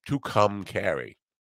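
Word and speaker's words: Tucumcari: You say it like a girl named Carrie who had two orgasms, two or made two guys come. To come, Carrie.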